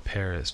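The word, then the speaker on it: PVRIS: The band I'm going to bring to you this week is called Paris, but it's not spelled like Paris. It's P-V-R-I-S.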